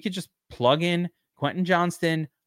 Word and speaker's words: Johnston: Oh, it is Johnston. So I said it right the first time.